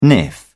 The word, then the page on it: niff: British English